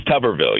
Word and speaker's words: Tuberville: It's Tuberville, right? Or Tuberville. How do you pronounce this guy's name? It is Tuberville, yeah.